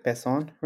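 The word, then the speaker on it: pezón: Yeah, pezon, like with a Z, but you really say it like pezon, really?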